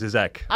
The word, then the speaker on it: Žižek: You said the name right. – Yeah, yeah. – And so I did not recognize it. – Zizek.